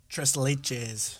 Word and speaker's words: tres leches: here, pronounce that for me. – Tres leches. – Thank you.